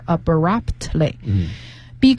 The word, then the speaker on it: abruptly: 没错，这里面 P 和 T 都是要发音的，只是说它比较轻，所以就是听的是 abruptly。Be careful not to do that in next week's presentation.